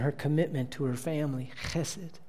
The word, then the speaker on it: Chesed: Chesed. Can you say that? Chesed. That's right. You can do that. Just spit comes out. You're saying it right.